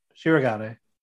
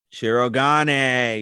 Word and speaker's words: Shirogane: Kaguya overheard Shigaran talking about... – Why are you saying Shirogane? – Yeah, it's his name. Shirogane. – Shirogane.